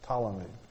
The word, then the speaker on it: Ptolemy: It starts with a P, but the P is silent, okay? Ptolemy.